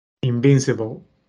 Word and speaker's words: Invincible: ¿Cómo se dice en inglés? – Invincible. Invincible. ¿Así? Ah, así mismo es.